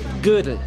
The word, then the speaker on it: Gödel: Kirk Goodell. – Gödel. Das wird Gödel ausgesprochen, weil da diese frechen Punkte auf dem O sind.